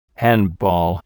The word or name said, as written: handball